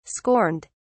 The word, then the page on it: scorned: British English